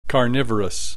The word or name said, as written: carnivorous